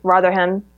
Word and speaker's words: Rotherham: This happened in Rotterdam. I'm not sure if I'm pronouncing it right. Rotherham. – Yep.